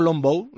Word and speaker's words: longbow: ¿Arco longbow, lo digo bien? – Muy bien dicho, sí.